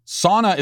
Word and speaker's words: sauna: Sauna is a Finnish word. In the Finnish language, each letter is enunciated, so it is pronounced sauna.